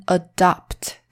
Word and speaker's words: adopt: 好，所以这个就是 adapt 跟 adopt 的差别哦。 A D A P T 还有 A D O P T 中间的母音不同。发音也不同，意思也不同，可是真的长得非常像哦。